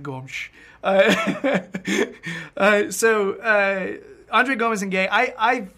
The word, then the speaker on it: Gomes: By the way, I looked it up. I did a little research. André Gomes. Gomes. Apparently, that's the Portuguese pronunciation. Now, if you're Brazilian Portuguese, it's just Gomes. Gomes. But Gomes. No one's doing it right.